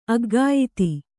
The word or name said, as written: ಅಖ್ಯಾತಿ